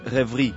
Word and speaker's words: Reverie: En realidad, en francés es Reverie.